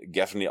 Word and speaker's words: Gaffney: Also ich war so irritiert davon, dass ich tatsächlich während des Films mal kurz Google befragen musste, wie Gaffney ausgesprochen wird, glücklicherweise genauso, wie wir es taten.